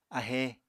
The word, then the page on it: ahe: Apatani